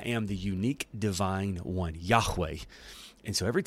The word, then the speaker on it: Yahweh: And the best transliteration we have for that is Yahweh, or if you want to put the ha in there, the Hebrew pronunciation, it would be Yahweh.